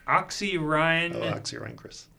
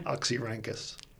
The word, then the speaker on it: Oxyrhynchus: Oxyrhynchus. – Say it again. – Oxyrhynchus.